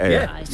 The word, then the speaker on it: Ayr: Am I saying it right, Ayr? – Yeah.